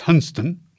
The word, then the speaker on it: Hunstanton: I was more than capable of pronouncing Hunstanton as Hunston, and I was confident that even Haysborough wouldn't catch me out.